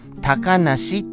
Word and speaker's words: 小鳥遊: そして、あのー、小鳥、あ、小鳥ですから、小さいの次鳥でした鳥は鳥です普通のはいその小鳥遊ぶと書いて高梨って読む